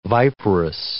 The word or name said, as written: viperous